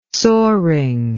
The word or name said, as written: soaring